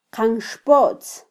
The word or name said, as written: qanxpotz